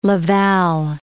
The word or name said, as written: Laval